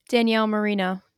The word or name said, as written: Marino